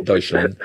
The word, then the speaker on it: Deutschland: Deutschland.